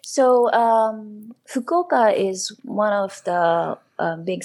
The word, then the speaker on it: Fukuoka: Fukuoka.